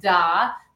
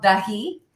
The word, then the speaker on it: Dahi: It's easy to think that it should be pronounced Da, because the last letter is indeed an exclamation point, but it actually is an I inverted. So it's, the brand is called Dahi.